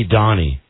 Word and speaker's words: Doni: Please call me Donnie, and I like to spell it D-O-N-I and Ed for 06.